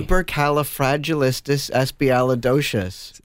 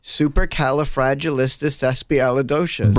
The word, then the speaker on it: Supercalifragilisticexpialidocious: Supercalifragilisticexpialidocious. – One more time. – Supercalifragilisticexpialidocious. – Break it down. – Super. – Yep. – Cala. – Yep. – Fragia. – Yep. – Licious.